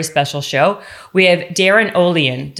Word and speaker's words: Olien: You can say say in Norway it's Ulian. – Ulian? – But I don't we don't pronounce it. I just found that out actually. It's pronounced Olien here.